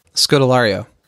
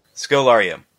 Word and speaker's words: Scodelario: Scodelario. – Scodelario.